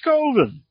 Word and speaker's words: Coven: It's pronounced Coven. Because Coven, that sounds like oven.